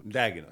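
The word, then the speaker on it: Daigneault: Dagnon.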